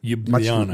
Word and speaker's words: Ljubljana: Ljubljana, much, much yeah.